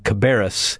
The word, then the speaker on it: Cabarrus: Cabarrus. I hope I pronounced that correctly. – That's right.